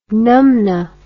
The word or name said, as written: numnah